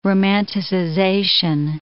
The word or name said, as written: romanticization